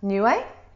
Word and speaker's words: Niue: So I was born on Niue Island. Not many people have heard about it. It's this little island between Fiji and Tonga, so in the Pacific. – Yeah. What's it called? – Niue. – Niue. – Yeah. – How do you spell that? – N-I-U-E. – N-I-U-E.